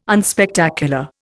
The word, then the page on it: unspectacular: Indian